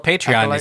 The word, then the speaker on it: Patreon: We even have a bunch of Patreon stuff for the High Republic to get ready for Eclipse. – First syllable of Patreon is pay. So Patreon.